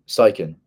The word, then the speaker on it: Steichen: So recently, earlier this week, I saw a tweet or something on Instagram, and it was breaking out the news that Steve Stachik, I don't know how to say his last name. – Steichen.